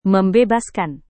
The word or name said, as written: membebaskan